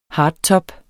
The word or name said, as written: hardtop